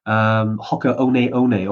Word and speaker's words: Hoka One One: Hoka One One, although it's spelled Hoka 1-1. It took us about 12 months to realize how to actually say that.